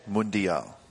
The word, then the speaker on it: Mundial: And help me with the pronunciation. Is it the Mundial? – Mundial.